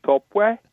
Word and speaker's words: Toppy: And you can tell Pastor Toppy I only mispronounced his name the first time when I called him Topi. – Really well. – And then after that, apparently to the Nigerians it's Topwe, but you know, he lets us use the English version.